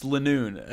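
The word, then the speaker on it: Linoone: I was literally thinking in my head, how in the world could we ever pronounce this one differently? It's Linoon. That's how I say it.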